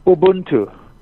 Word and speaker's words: Ubuntu: Ubuntu. Am I pronouncing it correctly? – Ubuntu.